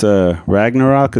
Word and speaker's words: Ragnarok: Ever since Ragnarok, is that what it's called? – Yeah. – Did I say it right? – That's the one.